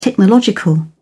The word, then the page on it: technological: British English